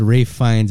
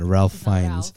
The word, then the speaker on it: Ralph Fiennes: 30-year-old His name is pronounced Ralph Fiennes. It's not Ralph Fiennes.